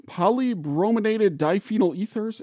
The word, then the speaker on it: polybrominated diphenyl ethers: The paper is called Patterns and Trends in, oh boy, Polybrominated Diphenyl Ethers in Bald Eagle Nestlings in Minnesota and Wisconsin, USA.